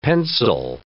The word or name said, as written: pencel